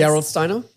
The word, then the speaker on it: Gerolsteiner: Gerolsteiner.